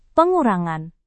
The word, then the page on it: pengurangan: Indonesian